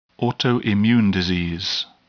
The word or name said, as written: autoimmune disease